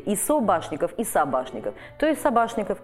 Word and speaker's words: Собашников: Он должен был быть Собачниковым, но он пишется и произносится как Собашников.